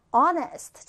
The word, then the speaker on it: honest: honest. 자, 발음에 주의한다. H는 발음하지 않는다라 그랬어요.